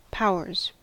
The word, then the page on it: powers: American English